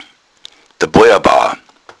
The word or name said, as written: boia